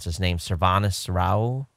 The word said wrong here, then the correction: Srinivas: Yeah, Srinivas.